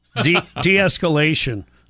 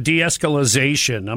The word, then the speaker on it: de-escalation: Sorry for adding syllables. De-escalation. I said de-escalization.